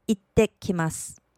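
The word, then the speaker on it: 行ってきます: 这个句子在い和て之间有个促音，所以请记得要停一拍。所谓的促音就是不发音，但是要停一拍。请跟着我再来练习一次，顺便留意促音的地方。いってきます。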